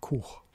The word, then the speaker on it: cwch: Also es wird geschrieben C-W-C-H, ausgesprochen Kuch.